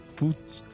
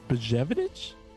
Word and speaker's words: Buchnevich: How do you say that? Pavel Buchnevich. – Buchnevich.